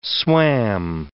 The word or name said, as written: swam